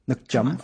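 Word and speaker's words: nước chấm: So actually, you mentioned, how do you pronounce it? Nguyen Dam? – Nguyen Dam. – Nook Chum. Okay. – Yeah, there you go.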